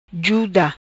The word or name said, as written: Juda